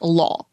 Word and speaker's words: Law: Low raider, so, you know, my last name is Law, right? – Yeah. – But in Chinese, it's pronounced Law.